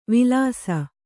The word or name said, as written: ವಿಲಾಸಿ